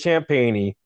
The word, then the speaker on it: Champagnie: Man, so it is Champagnie. Yeah. And you know what? He has a brother that played in the NBA last year. Did you know that? Who is that? Justin Champagnie for the Toronto Raptors.